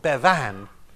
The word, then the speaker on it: Bevan: Now, the third most significant member of the government, I think, was Anarin Bevan. Churchill always used to put the emphasis on the second syllable because he rather liked Bevin, but did not like Bevan.